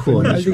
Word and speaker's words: Хомич: Хомич, я правильно говорю? – Хомич, Алексей.